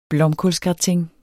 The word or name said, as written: blomkålsgratin